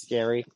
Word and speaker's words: Gary: Lorraine Gary is great. I believe I'm saying her name right. – Yes. – I think it's Gary.